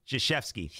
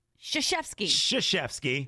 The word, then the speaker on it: Krzyzewski: Spelled with a K. – It's crazy, right? – Right. Not a S-H. – Krzyzewski. I'm trying to teach my son like how to pronounce words and letters and put things together. How am I supposed to teach him that? – Exactly. – Miles, spell Krzyzewski. S-H, it's a sh sound.